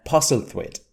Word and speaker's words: Postlethwaite: How would you pronounce that? – Postlethwaite. – Postlethwaite? Is that how the English say it?